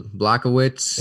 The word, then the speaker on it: Błachowicz: It's a hard name to pronounce. You got it for me. – Jan Błachowicz. – There we go, Błachowicz.